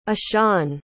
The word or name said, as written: ishan